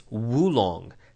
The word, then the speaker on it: oolong: So, oolong tea, O-O-L-O-N-G. That's how it's spelled. You can also find it as wulong, W-U-L-O-N-G, which is sort of more correct, but sort of two words.